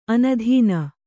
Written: ಆನೆದಿನ್

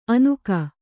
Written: ಅನುಗ

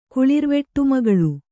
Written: ಕುಳಿರ್ವೆಟ್ಟುಮಗಳು

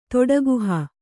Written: ತೊಡಗುಹ

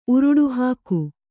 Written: ಉರುಡುಹಾಕು

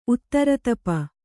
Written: ಉತ್ತರತಪ

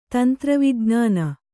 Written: ತಂತ್ರವಿಜ್ಞಾನ

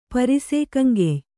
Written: ಪರಿಸೇಕಂಗೆಯ್

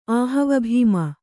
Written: ಆಹವಭೀಮ

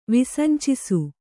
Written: ವಿಸಂಚಿಸು

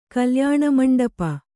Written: ಕಲ್ಯಾಣಮಂಟಪ

